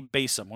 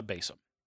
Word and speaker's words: besom: When we're talking about a broom, we're really talking about a besom. Besom, excuse me. – Besom. – That's what I meant. Besom. Thank you. – Besom. – You know, for 30 years, I've been pronouncing it besom.